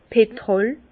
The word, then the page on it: 's Petrol: Bas Rhin; Herrlisheim